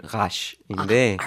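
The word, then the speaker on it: Rasch: 이게 독일어거든요. 네. 그래서 정확한 발음은 라쉬인데.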